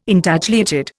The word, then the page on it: Intagliated: Indian